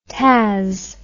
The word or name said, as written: taz